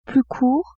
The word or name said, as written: plus court